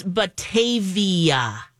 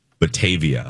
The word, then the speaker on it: Batavia: It's Batavia, Illinois. – Batavia. – Yes, long A.